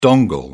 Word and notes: dongle: British English

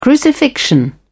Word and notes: crucifixion: British English

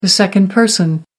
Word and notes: second person: American English

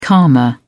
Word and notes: karma: British English

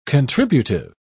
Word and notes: contributive: British English

